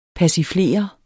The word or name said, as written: persiflere